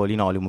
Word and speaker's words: Linoleum: Io Linoleum, nel senso dico Linoleum, però vedo che molte persone pronunciano Linoleum, che comunque non mi dispiace, è simpatico anche Linoleum.